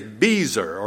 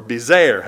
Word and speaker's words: Bezer: And then the fourth city is Bezer, or Bezer, however you want to pronounce it.